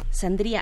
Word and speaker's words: Sandría: Bueno, también nos acompaña Alejandro Sandría, Sandría, así se pronuncia.